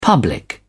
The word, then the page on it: public: British English